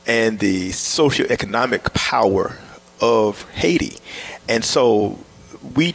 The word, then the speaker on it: Hayti: But if you look at the French map, if you look at the map, old maps, you will see Haiti spelled H-A-Y-T-I. Mm-hmm. Of course, they pronounce it Haiti.